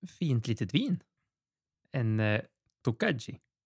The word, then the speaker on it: Tokaji: Ingen aning om det är så man uttalar det, men det står Tokaji. Tokai. – Tokai. Tokai. Tokai och Tokaji. Det är väl två olika beroende på vad det är.